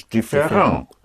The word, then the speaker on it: différent: Otherwise, if it is not the ending of a verb, then you pronounce it as ang. Yes. Like mm-hmm. different is différent.